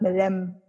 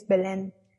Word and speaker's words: Belén: It's not Belém, it's Belén because it's like a city, like it's a city name.